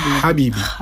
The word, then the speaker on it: habibi: On je to habibi. Není to ha, ale tady skrko habibi. Nebo marhaba.